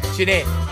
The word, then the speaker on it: Chinen: Darren Chinan, outstanding talent in Hawaii. Check him out. His last name is spelled Chinen. Chinan. All right.